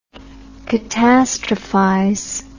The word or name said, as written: catastrophize